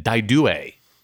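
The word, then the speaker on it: Dai Due: Daidue. – Oh, you pronounced it right. – Yes, I like my Italian.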